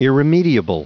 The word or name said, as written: irremediable